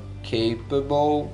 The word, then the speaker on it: capable: O que significa a palavra capable? Capable aí significa capaz. Capable. Lembrando a pronúncia, ó, mesmo de table final aí, BLE, vai ter o som de ou, oh, table, capable.